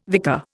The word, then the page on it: vicar: Indian